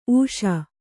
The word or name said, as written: ಊಸು